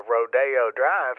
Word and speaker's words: Rodeo Drive: You got a lot more pornographic stuff in art galleries up and down Rodeo Drive, and yes, I said it right.